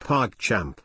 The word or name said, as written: pogchamp